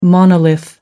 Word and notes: monolith: American English

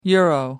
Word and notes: euro: American English

